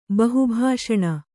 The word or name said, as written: ಬಹುಭಾಷಣ